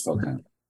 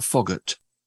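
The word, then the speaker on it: Foghat: It's probably pronounced Fog Hat. – It is Fog Hat. – It is Fog. It's not Foggart. – No, it's Fog Hat.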